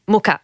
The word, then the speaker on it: Mucha: So Love Factually, Laura Mucha, Mucha is how you say it, but it's M-U-C-H-A.